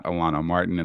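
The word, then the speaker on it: Alano-Martin: So all you got to do is just type in Tango in Philly or Matt Alano Martin, and I'll get you right there. My name is spelled a little odd. It's M-A-T and then A-L-A-N-O hyphen M-A-R-T-I-N.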